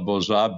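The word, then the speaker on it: Bourzat: Consciousness Medicine, I'm sure it's on Amazon, by Francois Borzat, Borza, for you listeners, and with a T at the end, but you don't pronounce it.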